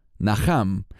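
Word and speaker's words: Naham (נחם): Esta palabra se deletrea Nun Het Mem. Se pronuncia Naham y significa consuelo.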